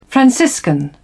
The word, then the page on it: franciscan: British English